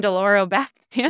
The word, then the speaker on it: durlobactam: Solbactam, Durlobactam. – Oh, no. – Breakpoints hosts, they're human too. – I was like, Solbactam, Dolorobactam.